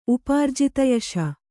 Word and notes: ಉಪಾರ್ಜಿತಯಶ: Kannada

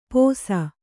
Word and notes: ಪೋಸ: pōsa

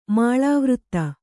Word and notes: ಮಾಳಾವೃತ್ತ: kannada; māḷāvřtta

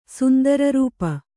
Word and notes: ಸುಂದರರೂಪ: Kannada

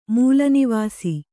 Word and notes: ಮೂಲನಿವಾಸಿ: kannada

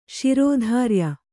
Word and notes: ಶ್ರೋತ್ರಿಯ: śrōtriya

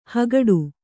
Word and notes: ಹಗಡು: hagaḍu

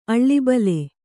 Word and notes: ಅಳಬಳ: Kannada

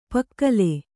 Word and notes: ಪುಕ್ಕಲಿ: pukkali

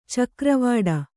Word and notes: ಚಕ್ರವಾಡ: cakra vāḍa

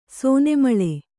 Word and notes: ಸೋನೆಮಳೆ: kannada

